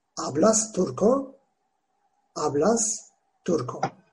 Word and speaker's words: Turco: Turko. Yazılışı da Trabzon, Uşak, Rize. K harfimiz yoktu. K ile C kullanıyoruz kalın ünlülerde. C o. Turco. Fakat okunuşu Turko.